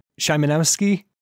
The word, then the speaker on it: Szymanowski: Szymanowski. – Very close. – Szymanowski.